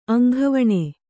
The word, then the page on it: ಅಂಗವಾನು: aŋgavānu